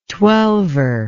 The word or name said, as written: twelver